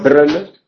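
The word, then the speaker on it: Brno: En checo, por ejemplo, hay una ciudad que se llama Brn, que son dos sílabas. Br, no, br, pues la primera sílaba, br, el núcleo de la sílaba es esa R, que es una líquida.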